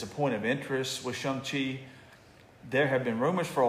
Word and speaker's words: Shang-Chi: And I didn't know I'd been mispronouncing that this whole time until Kevin Feige pronounced it properly. I've been calling it Shang-Chi. – Oh, I have too. – That's what everybody does, but it's actually Shang-Chi.